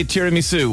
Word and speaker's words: tiramisu: I say tiramisu. What do you think of my pronunciation? – You are saying it perfectly.